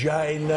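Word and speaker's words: Chyna: It's pronounced China.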